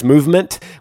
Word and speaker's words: MVMT: Movement, which is spelled MVMT, grew into one of the fastest growing watch brands, shipping to over 160 countries across the globe.